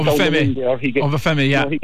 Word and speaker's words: Obafemi: Like, Obama, I can't pronounce his name, Obama, he's the man of the match. He was brilliant last night. – Yeah, you know, Obafemi, yeah.